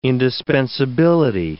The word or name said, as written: indispensability